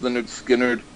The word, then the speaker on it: Lynyrd Skynyrd: And so I heard Lynyrd Skynyrd's album pronounced Lynyrd Skynyrd.